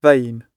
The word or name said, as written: vain